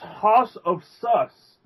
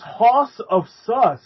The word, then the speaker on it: Hoss of Sus: It's hard to pronounce, so it's Hasosfss. It's like Hausofssus. – It's Hoss, it's Hoss of Sus. – Hoss? – It's Hoss of Sus, man.